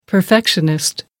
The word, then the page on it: perfectionist: American English